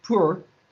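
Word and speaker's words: pur (πῦρ): And the Koine Greek term, by the way, for fire, in English would be pronounced pur, and that is grammatically neuter.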